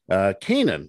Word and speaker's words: Kaenon: Kanan is the way you pronounce the sunglasses. It's spelled K-A-E-N-O-N. It's a way to make your outdoor experiences better. Kanan sunglasses made exclusively with polarized lenses for optimal clarity.